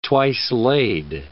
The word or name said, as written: twice-laid